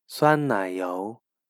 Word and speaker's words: 酸奶油: Sour cream is 酸奶油, 酸奶油.